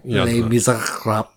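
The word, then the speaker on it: Les Misérables: Les Miserables.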